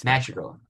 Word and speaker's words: Madrigal: He's Madrigal. Anyways, send him in half. They can take him in half. He's a top level talent. – There's no accent on the A in Madrigal.